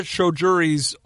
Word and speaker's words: Choudhury: They were Hamza Choudhury's only three league starts of the season.